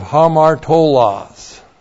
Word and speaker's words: hamartolos: And then we have the adjective hamartolos. That's H-A-M-A-R-T, long O-L-O-S.